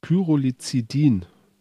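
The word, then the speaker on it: Pyrrolizidin: Und zwar enthält der Boric kleine Mengen von Pyro, Pyrolizid Alkaloide. Pyrolizidin. Pyrolizidin-Alkaloide, so wird es genau mhm, ausgesprochen.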